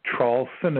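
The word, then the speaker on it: Traulsen: My name is Hugh, H-U-G-H, last name Trawlson is spelled T like Tom, then R-A-U-L-S like Sam, E like Edward, N like Nancy.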